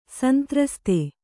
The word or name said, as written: ಸಂತ್ರಸ್ತ